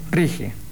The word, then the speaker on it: RIGI: Lo cierto es que, además de la cuestión Adorni, había que debatir el llamado super Rigi, o Rigi, o Rigi, no sé cómo se pronuncia. – Rigi. – Bien.